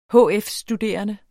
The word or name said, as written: hf-studerende